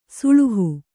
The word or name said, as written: ಸೋಳಹ